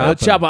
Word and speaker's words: El Chapo: Did you say El Capo? – It's El Chapo. – El Chapo. I will not answer. – Hey, I'm not answering that question. – You have to pronounce the H.